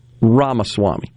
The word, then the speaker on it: Ramaswamy: And his name is Vivek Ramaswamy. I think I said that right. Ramaswamy, yeah.